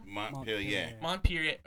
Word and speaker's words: Montpelier: I can't even pronounce this word. It's like Montpelier, Ohio. – Montpelier. – Okay. Thank you, Mr. Frenchie.